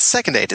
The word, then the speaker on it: Second Aid: It's Second Aid. It's like First Aid, but the next one.